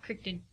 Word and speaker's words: Crichton: Crichton. – Crichton? – Right.